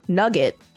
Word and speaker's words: nougat: This is known as a single nougat in Scotland, with a double nougat, pronounced nugget, having nugget wafers on both sides.